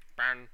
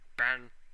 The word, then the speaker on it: Ben: Ben. – I'm sorry, what was that? – Ben. – How do you spell that? – B-E-N.